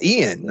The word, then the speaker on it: Ian: It's Ian, though. That's how you say that. – No, it rhymes with Iron Eagle. The Iron Eagle. – It rhymes with Ryan.